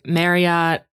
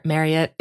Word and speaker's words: Marriott: It depends on what program you're talking about, but like Marriott, I think I said that wrong. Marriott.